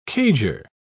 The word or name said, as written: cager